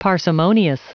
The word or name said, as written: parsimonious